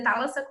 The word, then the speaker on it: Thálassa: Então muita gente me chama de Talassa, erra um pouquinho a pronúncia, mas é muito normal. Mas é Talassa Coutinho em todos, assim, site, LinkedIn, Instagram.